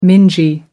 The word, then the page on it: mingy: American English